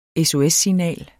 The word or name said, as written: SOS-signal